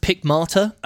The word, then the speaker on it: Pickmate: I think it's pronounced Pickmarter. – It is Pickmarter. – It's like the German drink.